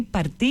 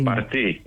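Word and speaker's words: Parti: Το νέο κόμμα λοιπόν, αυτό το Γενή Παρτί ή Πάρτι, πώς το προφέρουν οι Τούρκοι? – Παρτί. – Παρτί, καλά το φαντάστηκα, ναι, καλά το φαντάστηκα.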